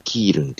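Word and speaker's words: 基隆: はい、キールンというのは基本の木という字に、タカシと書いてキールンと読むんですけども、このキールンの港町なんですね、キールンというのはね。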